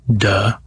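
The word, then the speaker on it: duh (德): Yeah, so if you are in this state, you also have this power, duh. So it's fortunately pronounced that way in Mandarin.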